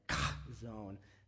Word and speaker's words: chazown: And it's actually spelled C-H-A-Z-O-W-N, kind of crazy, because it's a Hebrew word. And what it means is vision. And actually, I'm saying it improperly. If you want to say it correctly, you have to hack up like a ball of phlegm when you say it. It's like Kazon.